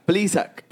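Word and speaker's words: Plesac: Pero el otro lanzador envuelto, Zach, diablo, ¿cómo se pronuncia eso, Manny? Ayúdame. Plisak, Plisak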